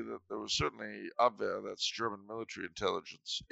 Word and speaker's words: Abwehr: Abwehr. Abwehr. – A-B-W-E-H-R.